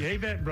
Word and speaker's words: Avett: You said the Avett Brothers. – Avett Brothers. How do you say it? – That's what I say. – Oh, okay. – I'm just joining in. – No, I thought there was a pronunciation issue, because I know people have debated the Avett. – Oh, that's right. That's true. – So I'm hoping that's correct. – I think it is.